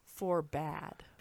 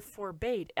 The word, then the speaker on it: forbade: The other one is Forbade. – Oh, Forbade. – Instead of Forbade. I know it's spelled Forbade in the book. I'm pretty sure it's spelled Forbade in the book with an E on the end. But the audiobook pronounces it Forbade.